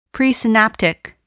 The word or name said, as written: presynaptic